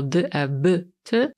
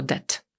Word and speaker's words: debt: Pewnie już to kiedyś mówiłam, ale zwracam uwagę na słowo debt, czyli dług, który zapisujemy jako debt, a litera B jest nie ma. I wymawiamy jej, znaczy nie wymawiamy jej wcale. Mówimy tylko debt, bez B.